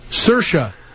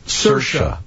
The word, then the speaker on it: Saoirse: Saoirse. Saoirse is the queen from... – Game of Thrones. – Oh. – Saoirse.